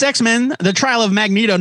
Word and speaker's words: Magneto: It's Magneto, yeah.